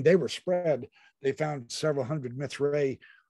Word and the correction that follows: Mithraea: I don't even know if I'm pronouncing that right. It's Mithraea.